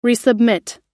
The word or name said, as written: resubmit